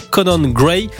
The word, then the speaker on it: Conan Gray: Vous voulez nous présenter un certain Conan Gray. Je prononce bien ? – Très bien.